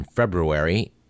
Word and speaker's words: February: On this date in history, February 9th, and by the way, there are two R's in February, and both are pronounced.